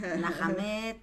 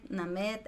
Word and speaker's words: Nahameth: Ah, hay veces que me dicen Nahamed, Nahamed, Nahamed, Name, no importa, indistinto acostumbradísima.